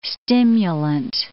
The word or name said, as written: stimulant